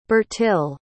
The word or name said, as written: Bertille